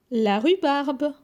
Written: La rhubarbe

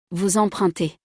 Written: vous empruntez

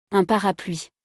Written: Un parapluie

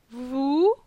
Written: Vou

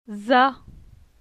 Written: Za

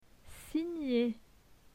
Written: signer

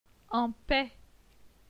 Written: En paix

